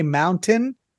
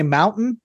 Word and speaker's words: mountain: You know, it's funny, in Utah, a lot of people don't say mountain. They say mountain. – Mountain. – Like, yeah. There's no T in their pronunciation. – Well, nothing has a G here, by the way. – That's not how you say it. I thought it was mountain. – No, it's mountain. There's a T in it.